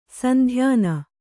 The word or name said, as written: ಸಂಧ್ಯಾನ